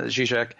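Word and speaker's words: Žižek: So, yeah, Zizek.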